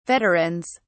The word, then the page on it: veterans: British English